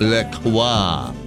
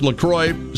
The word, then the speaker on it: La Croix: So the official, and I'm so sorry, the official pronunciation is not Le Croix. Not so fancy schmancy, because it's from Wisconsin, after all. There's no cheese in it yet. It is pronounced La Croix, so it rhymes with enjoy.